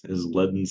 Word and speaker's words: Leaden: It is Leaden Salute. – Oh, L-E-A-D, L-E-A, it's lead, my bad.